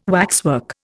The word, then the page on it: waxwork: Indian